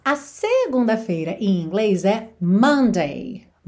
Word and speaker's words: Monday: Se escreve com O-M-O-N-D-A-Y, mas esse O não vai ter esse som Monday, tá? E sim Monday, Monday. O mais próximo, se a gente pensar em português, é Monday.